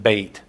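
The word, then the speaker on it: Beit (בית): You've probably have heard me give you this information before from Bible study, but B-E-T-H is the Hebrew word for house. B-E-T-H, we would say Beth. They pronounce it Beit.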